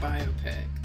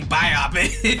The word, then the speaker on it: biopic: It's pronounced biopic. – It's just like, you keep saying biopic.